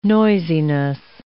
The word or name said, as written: noisiness